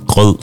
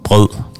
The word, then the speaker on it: brød: Brød. Brød.